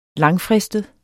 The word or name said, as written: langfristet